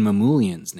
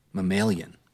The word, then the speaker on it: Mamoulian: You pronounced Ruben Mamoulian's name wrong last week. That's what you did. – How do you say it? – Mamoulian. – What did I say? – You said like mammalian.